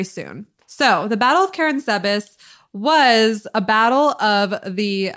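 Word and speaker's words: Karánsebes: So the Battle of Karen Sebas. Is that how he said it? Do you remember? – Yeah, it is. – Okay, great. Karen Sebas.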